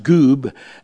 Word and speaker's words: guwb: and you get the letters G U W B, uh, which is pronounced goob,